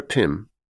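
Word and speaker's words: Pm: His daughter Pim, spelt P-M, pronounced Pim,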